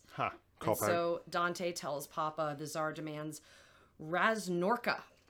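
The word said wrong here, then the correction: Razborka (разборка): Yeah. Razborka. – Razborka. – Razborka. – Razborka, thank you.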